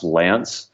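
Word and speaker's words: LAANC: So I'd say that the FAA is, and it's spelled like Lank, but it's pronounced Lance, you know, typical kind of government soup, alphabet soup.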